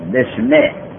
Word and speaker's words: بسم: در سوره اقرأ اونجا دارد اقرأ به اسمه، الف را نوشتن لیکن تلفظ نمی کنن، میگن بسم.